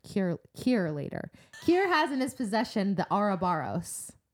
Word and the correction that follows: Ouroboros: Ouroboros. Is that how they say it in the um, audiobook? – Ouroboros. I say Ouroboros.